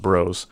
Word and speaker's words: Broz: His last name is spelled B-R-O-Z, but it's pronounced Bros.